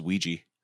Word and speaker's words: Ouija: Also, the protagonist's name is Ouija. Like Ouija board. Fucking so cool. – Ouija. Thank you. – It's pronounced Ouija.